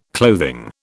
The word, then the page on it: clothing: British English